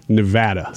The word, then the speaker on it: Nevada: You a Nevada or a Nevada guy? – Nevada. – Same. I wonder if it's wrong. – No, it's correct. – Nevada? – Yeah, that's how you say it if you're in Nevada.